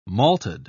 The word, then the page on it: malted: British English